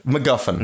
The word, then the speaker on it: MacGuffin: He doesn't say it like that. – He says it with an accent. – But MacGuffin? – MacGuffin. Mm. That's how it's pronounced.